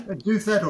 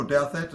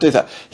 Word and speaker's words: Douthat: Douthat or Douthat, either one, yeah. – Right, Douthat.